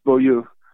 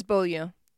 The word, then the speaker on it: Beaulieu: But when I heard you speak in California last year, did you say Beaulieu? – No, it's Beaulieu. – It is Beaulieu.